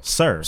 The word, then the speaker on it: SiR: How you pronounce my man's name, man? Oh, TDE, man. The album's nice, though. – Sir. – Sir, okay. Just want to make sure I know. – And how's it spelled? – Sierra. It's S-I-R, but I want to double check.